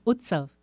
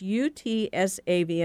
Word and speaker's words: Utsav: It's it's Utsav. See, it's U T S A V, and I thought it was just the letters that represented something, but it's Utsav.